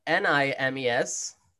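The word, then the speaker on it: Nîmes: How do you pronounce that? I N I M E S. Nimes names. Uh, it's kind of like memes, but with ends.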